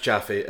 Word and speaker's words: Jaffe: Written by the Jaffe brothers. I don't know if that's how you pronounce it. – Yes, it is pronounced Jaffe.